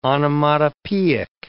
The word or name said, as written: onomatopoeic